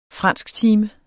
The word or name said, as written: fransktime